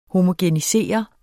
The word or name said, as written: homogenisere